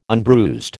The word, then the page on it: unbruised: American English